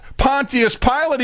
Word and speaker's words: Pontius Pilate: Matter of fact, Pontius Pilate even said,